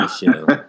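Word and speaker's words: Michel: Michelle, ain't that how you pronounce his name? – Sony, yeah. Michelle.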